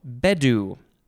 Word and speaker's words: bedu: Next, we have bedu. No, bedu. I think the emphasis is on the first syllable. B-E-D-U.